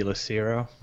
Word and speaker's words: Lacero: It would be a soft C, so it would be Lacero.